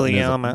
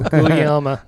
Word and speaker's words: Guglielma: Guglielma. – Like, Guglielma. – Yeah, I typed it in and it did the audio. Guglielma. That's exactly how it said, too.